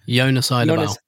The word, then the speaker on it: Eidevall: Jonas Eidevall. – Idabal.